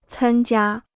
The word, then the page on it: 参加: cān jiā